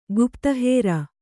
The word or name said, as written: ಗುಪ್ತಹೇರ